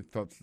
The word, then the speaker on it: thoughts: my thoughts exactly. C'est exactement ça me dépensait. Alors, on va prononcer thoughts là, il faut mettre l'anglais dehors comme ça. Fa, fa, fa.